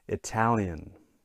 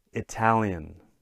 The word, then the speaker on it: Italian: Now, if we look at this, the nationality, Italian. Okay. Listen one more time. Italian. Okay. So in this case, the second syllable gets a stress.